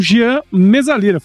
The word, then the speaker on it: Jean Mezzalira: E para falar com a gente aqui, estou com o Jean Mesalira, falei certo? Isso mesmo.